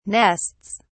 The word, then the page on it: nests: British English